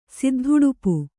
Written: ಸಿದ್ಧಉಡುಪು

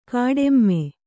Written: ಕಾಟಿಮೆ

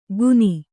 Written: ಗುನಿ

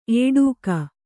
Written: ಏಡೂಕ

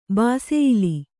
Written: ಬಾಸೆಯಿಲಿ

